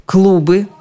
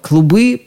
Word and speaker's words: клубы: В русском языке клуб, как организация, не отпускает ударение с первого слога ни в одном из падежей. Клуб, клубы, клубу, клубом, о клубе. Во множественном числе ударение тоже неизменно на первом слоге. Клубы, клубов и так далее. Но бывает и совсем другой клуб. Летящая масса дыма, пыли. Клубы дыма, клубы пыли. Они как раз клубы, чем и отличаются от клубов, то есть организаций.